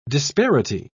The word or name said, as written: disparity